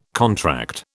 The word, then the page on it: contract: British English